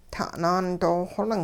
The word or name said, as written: ta'naan doo-xoling